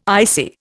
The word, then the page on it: icy: Indian